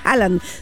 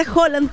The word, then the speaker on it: Haaland: Porque no se dice Haaland, se dice Haaland como él mismo lo dijo.